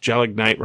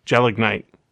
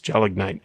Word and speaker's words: gelignite: he pronounced Gelignite wrong. He said Gelignite? Gelignite. It's Gelignite. It's like pre-dynamite.